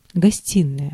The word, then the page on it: гостиная: /ɡɐˈsʲtʲinəjə/; gostinaja